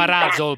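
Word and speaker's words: Ruzzle: si chiama Razzol pazza. – Poi comunque lasciamo perdere Razzol, comunque lasciamo perdere. – Vabbè, però si scrive Ruzel, no? – Sì, beh, ho capito, sì, però la scrittura e la dicitura sono due cose diverse.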